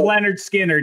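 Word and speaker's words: Lynyrd Skynyrd: Pronounced Leonard Skinner is definitely has the best songs by Skinner on it and deserves a shout, too.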